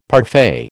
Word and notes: parfait: American English

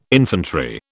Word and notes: infantry: British English

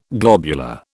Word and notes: globular: British English

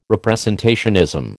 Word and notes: representationism: American English

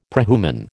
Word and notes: prehuman: American English

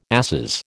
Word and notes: asses: American English

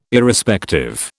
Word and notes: Irrespective: British English

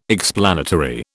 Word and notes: explanatory: British English

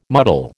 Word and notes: muddle: American English

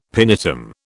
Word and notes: pinetum: British English

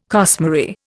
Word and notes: costmary: Indian

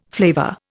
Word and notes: flavour: Indian

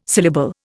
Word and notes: syllable: Indian

